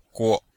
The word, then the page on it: guo: guo5